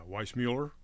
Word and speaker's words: Weissmuller: Yeah, Weissmuller.